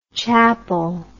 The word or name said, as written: Chappell